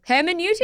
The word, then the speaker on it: Hermeneutics: Hermeneutics.